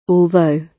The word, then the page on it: although: British English